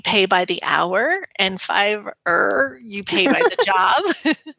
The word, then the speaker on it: Fiverr: It's like Fiverr, yeah. – Yeah. – But it's like 2 R's, right? Fiverr. – Yeah, that's exactly it.